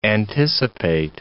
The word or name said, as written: anticipate